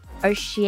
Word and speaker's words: Oceania: Oceania.